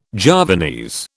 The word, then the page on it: Javanese: British English